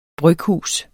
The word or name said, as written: bryghus